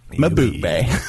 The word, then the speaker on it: Mbube: Mbube. – Hey, did great. – The fourth grader in me is dying right now. – It's Mbube. My boobay.